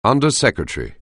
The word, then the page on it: undersecretary: anglais